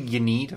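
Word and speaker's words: Ynnead: It's usually Yiniad. I've heard people pronounce that.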